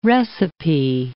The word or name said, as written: recipe